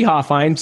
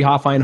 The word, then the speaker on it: Hofhine: So Cody Hofhine. So find me on social media, Cody Hofhine. Hofhine is spelled Hofhine.